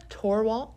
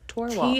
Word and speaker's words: Torwalt: Torwalt. – Torwalt. – T-O-R-W-A-L-T.